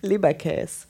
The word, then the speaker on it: Leberkäse: How do you say the name in, in German? Leberkäse. – That's, that's it.